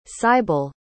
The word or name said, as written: sybal